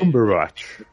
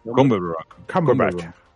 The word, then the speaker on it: Cumberbatch: Cumberbatch. – Cumberbatch.